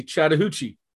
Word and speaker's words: Chattahoochee: I'm going to pronounce it correctly, Chattahoochee. – Mm-hmm. That's us, Chattahoochee County, and we kind of call ourselves Chatco. – Cool. And so where are you in the state of Georgia? – Yeah, well, Chattahoochee is a small rural system in the southwest corner of Georgia.